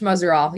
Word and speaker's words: Muzerall: Coach Muzerall.